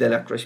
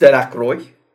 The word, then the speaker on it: Delacroix: Dicky Delacroix diye birisi var ya da Richie Delacroix. Şimdi adamın adını unuttum. Fakat köylüler bunu yanlış telaffuz ederlerdi. Delacroix derlerdi ona. Niye bir muhabbet var. Şimdi ben ona baktım. Fransızca'da zaten bu isim Delacroix diye okunuyor.